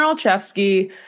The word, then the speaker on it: Olszewski: but Gunnar Olszewski, that's how you say it, right? I hope I'm saying it right. – Yes. Yep. – Gunnar Olszewski, he was the NFC Special Teams Player of the Week this week.